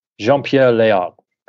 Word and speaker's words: Jean-Pierre Léaud: They had an interview with the actor who plays him, let's say Jean-Pierre Léaud. – Jean-Pierre Léaud, correct.